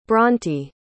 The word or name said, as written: bronte